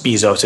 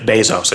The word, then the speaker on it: Bezos: And by the way, his name is not pronounced Bezos, it's Jeff Bezos. I just want it, in case you were wondering. I wasn't sure about it, but I verified. Jeff Bezos.